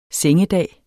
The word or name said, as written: sengedag